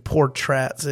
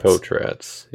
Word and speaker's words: Potratz: So there's only the second R is the real one. – Okay. – First one is silent. – I thought that would, had been established as well, that it wasn't Portraz. It was, how did you say it again? Potratz? – Potratz, yeah. – Okay. – Or like, it's spelled like pot rats with a Z.